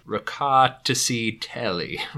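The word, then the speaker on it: Rkatsiteli: So this week I chose a wine, and we're going to butcher it, but I actually wrote out the pronunciation on here. It's Raca Tsi Teli. Raca Tsi Teli.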